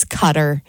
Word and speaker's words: Qatar: And then also on here was Qatar, which I say wrong as well. It's Cutter.